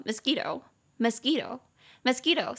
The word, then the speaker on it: mosquito: 好，所以那个蚊子，顺便帮大家复习一下，蚊子念作 mosquito，mosquito，mosquito，三个音节，m m o ski s q u i to t o mosquito，中音节在第二音节 mosquito，所以这个字你平常用的话，你就可以直接说 I hate mosquitoes，我恨蚊子 I hate mosquitoes，也可以直接这样用。